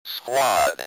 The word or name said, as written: squad